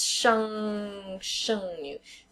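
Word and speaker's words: sheng nu (剩女): So it's sheng, fourth tone, nu, which is like one of those weird ones to pronounce, but it's N with a U and an umlaut over the top, mm-hmm. and the third tone, nu, which means girl.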